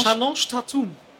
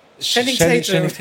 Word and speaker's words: Channing Tatum: Chalange Tatum. – Channing Tatum.